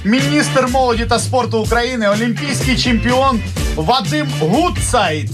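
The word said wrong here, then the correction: Гуцайт: Гудсайт, да, немножко ударение неправильно поставили, окей.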